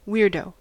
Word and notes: weirdo: American English

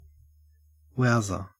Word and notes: wowser: Australian English

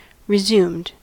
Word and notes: resumed: American English

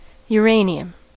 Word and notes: uranium: American English

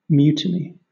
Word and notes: mutiny: British English

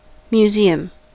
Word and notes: museum: American English

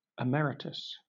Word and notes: emeritus: British English

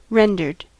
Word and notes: rendered: American English

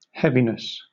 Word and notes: heaviness: British English

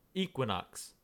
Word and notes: equinox: American English

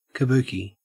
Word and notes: kabuki: Australian English

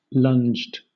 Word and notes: lunged: British English

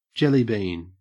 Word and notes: jellybean: Australian English

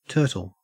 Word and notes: turtle: Australian English

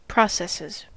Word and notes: processes: American English

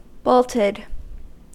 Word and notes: bolted: American English

